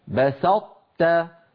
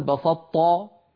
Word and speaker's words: بسطت: لئن بسطت إلي يدك، بسطت فأطبقت لساني على طاء غير مقلقلة وجافيته عن تاء مرققة، لا أقول بسطة، لا أقول ذلك، يعني لا أنطق طاء مشددة، أعوذ بالله، هل ندغم الأول في الثاني أم الثاني في الأول؟